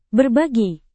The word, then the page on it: berbagi: indonesian